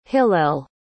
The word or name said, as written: hillel